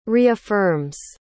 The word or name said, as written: reaffirms